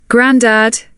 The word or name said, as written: grandad